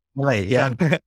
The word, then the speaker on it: Milei: Am I saying Milei right? – Milei's good, yeah. – Milei, yeah.